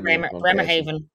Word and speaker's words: Bremerhaven: Bremerhaven. – Bremerhaven.